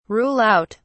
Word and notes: rule out: British English

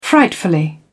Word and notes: frightfully: British English

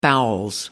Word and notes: bowels: British English

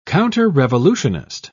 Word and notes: counterrevolutionist: British English

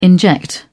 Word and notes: inject: British English

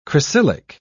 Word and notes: cresylic: British English

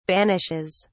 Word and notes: banishes: British English

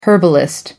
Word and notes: herbalist: American English

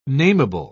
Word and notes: nameable: British English

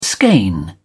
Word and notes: skein: British English

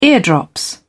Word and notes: ear drops: British English

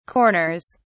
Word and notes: corners: British English

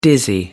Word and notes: dizzy: American English

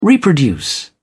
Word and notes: reproduce: British English

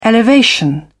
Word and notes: elevation: British English

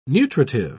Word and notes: nutritive: British English

